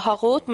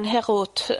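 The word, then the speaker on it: Herut (חרות): Ikke uttalt av Harut, men Herut.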